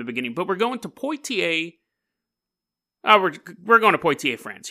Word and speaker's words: Poitiers: We're going to Poitiers, France, and you guys are shocked that I pronounced that right. It's the only reason I pronounced that right because I was in a play. I was King Richard the Lionheart, and I kept calling it Poitiers, and they're like, it's not Poitiers, it's Poitiers, which is stupid.